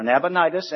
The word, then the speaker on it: Nabonidus: Nabonidus, or Nabonidus, as some pronounce it, was the father, and Belshazzar was the grandson, or the son of Nabonidus.